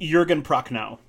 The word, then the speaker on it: Jürgen Prochnow: of February 1995, starring Sam Neill, Julie Carmen, and you're going to help me out with this one, Jürgen Prochnow. Is that how you pronounce that? – Jürgen Prochnow.